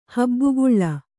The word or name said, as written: ಹೆಬ್ಬಾಗಿಲು